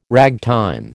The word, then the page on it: ragtime: American English